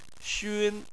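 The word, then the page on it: xun: tone 1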